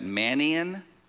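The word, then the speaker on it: Manaen: This next guy's name, I kept listening to it over and over so I could pronounce it right. It's Manaane. Okay, that's how you pronounce it.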